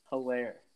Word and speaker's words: Helaire: Clyde Helaire.